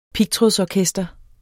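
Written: pigtrådsorkester

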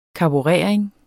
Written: karburering

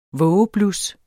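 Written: vågeblus

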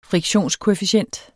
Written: friktionskoefficient

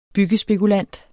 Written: byggespekulant

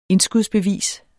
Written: indskudsbevis

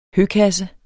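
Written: høkasse

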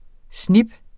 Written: SNIPPER

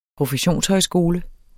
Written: professionshøjskole

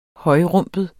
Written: højrumpet

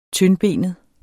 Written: TYNDBENET

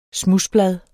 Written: SMUDSBLAD

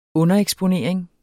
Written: undereksponering

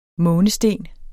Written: månesten

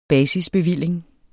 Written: basisbevilling

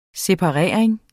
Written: separering

